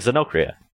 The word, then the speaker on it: Xenochria: Um, oh, like Xenocria.